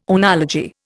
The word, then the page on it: oenology: Indian